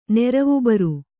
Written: ನೆರವುಬರು

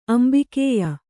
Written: ಅಂಬಿಕೇಯ

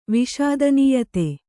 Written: ವಿಷಾದನೀಯತೆ

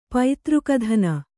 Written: ಪುತ್ರಘಾತಿನಿ

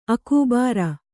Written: ಅಕೂಬಾರ

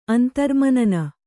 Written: ಅಂತರ್ಮನನ

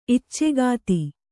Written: ಇಚ್ಚೆಗಾತಿ

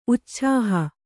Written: ಉಚ್ಛಾಹ

